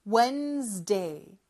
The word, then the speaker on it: Wednesday: 그런데 실제로 D 발음은 생략을 합니다. 그래서 Wednesday 이렇게 발음을 해요. D 발음은 생략하고 어떻게 한다고요? 그쵸. Wednesday, Wednesday라고 해요. D 발음 뺐다고 Wednesday라고 하면 안 됩니다.